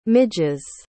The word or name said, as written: midges